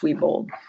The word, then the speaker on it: Wiebold: And then I have a business Facebook page, which is Terry Weibold, Your Healing Insights. – Can you spell your last name? So in case people don't know how to spell it. – W-I-E-B-O-L-D, pronounced Weibold.